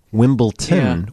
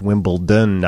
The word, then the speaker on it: Wimbledon: Do you remember a couple years ago, a guy had an answer on Jeopardy where the answer was Wimbledon, but he said Wimbledon, which is what, seriously, most people say. Even though it's spelled D-O-N, more people say Wimbledon than Wimbledon. So in that instance, and that was for a victory on Jeopardy, nope, you don't get it. It's Wimbledon, not Wimbledon.